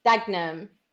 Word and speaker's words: Dagenham: Like, I got told you don't say the third syllable, like the second syllable in Dagenham is Dagenham.